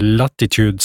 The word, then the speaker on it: Latitude: Og det her gode selskapet, da, Latitude, jeg tror det uttales Latitude 65, de driver på å produsere da i Jorn, heter det, i Sverige.